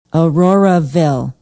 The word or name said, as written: auroraville